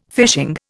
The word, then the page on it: fishing: Indian